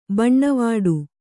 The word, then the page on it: ಬಣ್ಣವಿಡು: baṇṇaviḍu